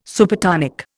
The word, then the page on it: Supertonic: Indian